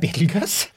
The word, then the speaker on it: Betelgeuse: Og da sier han, aller først, hvordan uttales navnet på stjerna? Det har jeg da svart på, det Betelgeuse. Det hvert fall sånn vi vanligvis gjør det i Norge.